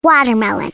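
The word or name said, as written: watermelon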